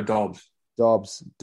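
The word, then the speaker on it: Doubs: Is it Dobbs? Is that how you say his name? Romeo Dobbs.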